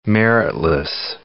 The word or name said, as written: meritless